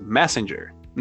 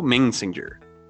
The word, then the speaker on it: Messenger: E esta é a pronúncia correta, Messenger, não Messenger.